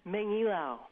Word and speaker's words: Mangilao: Is a contributing reporter to KPRG, our member station in Guam, and she joins us now from her home in Mangola. Did I pronounce that correctly or anywhere close to correctly? – Mangilao.